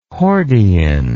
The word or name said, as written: hordein